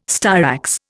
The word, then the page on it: styrax: Indian